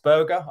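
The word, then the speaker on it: Burger: This is Wout Berger, I think, and it's actually pronounced, spelt like hamburger.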